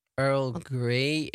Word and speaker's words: Earl Grey: En Gay Vanessa? Nee, niet gay. E-Griekse ei. Hoe spreek je dat uit? Gay. Spreek je het uit, denk je? – Earl Grey.